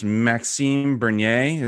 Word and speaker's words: Maxime Bernier: And I'm probably pronouncing his name wrong, but this Maxime Bernier, is that how you say it? – Yep.